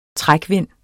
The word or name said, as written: trækvind